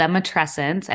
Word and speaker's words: Matrescence: And it's one of the first questions that we typically get is, how do you say it? So you said it right. It's the Matrescence.